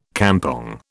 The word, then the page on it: kampong: British English